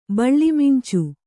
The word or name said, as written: ಬಳ್ಳಿಮಿಂಚು